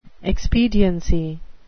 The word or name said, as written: expediency